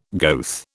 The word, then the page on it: goeth: British English